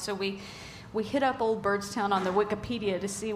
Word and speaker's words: Byrdstown: Yeah, Byrdstown.